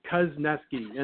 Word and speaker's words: Kuzneski: Yeah, I tell people that it rhymes with was pesky, Kuzneski, and that, they're able to remember that.